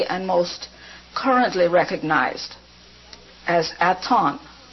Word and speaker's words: Aton: In symbolic language, that's H-A-T-O-N-N. The first symbol, which you call an H, is silent. The last N, which you call a little humpbacked symbol, is also silent. But it is so that you will not call me Hatton, H-A-T-T-O-N. I am not Hatton. My name is Yorgos, G-Y-E-O-R-G-O-S, Ceres, C-E-R-E-S, Hatton or Aton. Put an apostrophe, A-T-O-N, apostrophe.